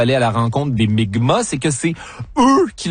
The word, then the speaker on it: Mi'kmaq: la nation Micmac, qui se prononce en fait Mi'kmaq, dans ce qui sera le premier contact.